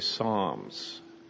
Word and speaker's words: Psalms: In our English language, we spell it P-S-A-L-M. In Greek, it would be spelled Psi, would be the first letter. And for us, we just kind of drop the P. We make it a silent P and we say Psalms.